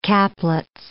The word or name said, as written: Caplets